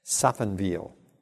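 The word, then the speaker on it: Safenwil: So if you want a pronunciation of this little town, Saffenwil.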